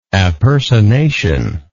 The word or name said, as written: appersonation